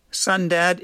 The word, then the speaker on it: Sundad: Sundad is the father and son duo of John Urell Sr. and John Urell Jr. It's spelt S-U-N-D-A-D.